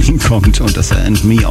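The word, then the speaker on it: Âme: Am Anfang habe ich ihn Arm ausgesprochen. Tatsächlich wird aber And Me ausgesprochen und ich habe ihn sogar für eine Frau gehalten.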